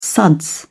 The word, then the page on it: suds: British English